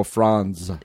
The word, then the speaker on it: Franz: No, F-R-A-N-Z. – Oh, Franz. – Yes, Franz. The T is silent. – The T is silent, like giant, like the D.